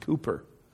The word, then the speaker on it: Cowper: I think of William Cowper, sometimes I mispronounce his name. It's actually pronounced Cooper.